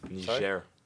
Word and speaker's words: Niger: Niger.